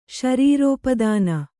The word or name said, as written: ಶರೀರೋಪದಾನ